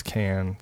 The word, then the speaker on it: Cannes: Some people call it con, some people call it... But it's Cannes. Technically, it's Cannes. That's the proper pronunciation. The Cannes Film Festival. – Okay. – Why is everyone looking at me like I'm wrong? It's got an S on the end. Yeah, I know. It's a silent S.